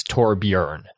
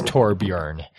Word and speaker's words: Torbjörn: And yes, the proper pronunciation, I've checked with actual Swedish people, the proper pronunciation of it is Torbjörn. The O with the umlauts is kind of a E sound, kind of a sound. So it's Torbjörn.